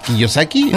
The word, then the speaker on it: Kiyosaki: Oigan, vámonos mejor a la nota, Gaby, que es acerca de, pues, este Robert Kiyosaki, así como se pronuncia correctamente, autor de el libro Padre Rico, Padre Pobre.